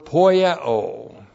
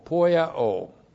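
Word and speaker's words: poieo (ποιέω): And then we have a verb poieo. P-O-I-E, long O, poieo.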